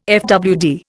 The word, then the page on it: fwd: Indian